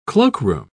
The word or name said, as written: cloakroom